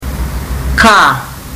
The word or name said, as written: ka